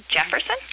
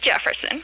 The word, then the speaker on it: Jefferson: So many people, the name Jefferson is not uncommon in Brazil, but with the pronunciation of Portuguese, Brazilian Portuguese, if it's spelled the way that we spell Jefferson, starting with the J, mm-hmm. – it's a soft J, and so it's Jefferson. – Mm-hmm. – So some people put in a D before the J to make a strong J, Jefferson.